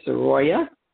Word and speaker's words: Soraya: My name is Soroya, S-O-R-A-Y-A, Van Horn. So Soroya's named after me. Girls normally are not juniors, but mine's Soroya, S-O-R-A-Y-A, pronounced the same.